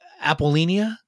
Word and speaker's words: Apollonia: Apollonia on her, how do you pronounce Apollonia? – Apollonia.